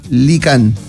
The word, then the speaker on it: Lykan: Escribe, amigo, para que ustedes sepan, lo busquen, se escribe L-Y-K-A-N. Lycan.